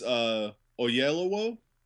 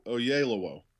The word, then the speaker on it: Oyelowo: It's Oyelowo. – Okie dokie. – Oyelowo is how you say it. And, all I know is they sing that at soccer matches. – David Oyelowo is amazingly good.